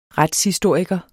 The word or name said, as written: retshistoriker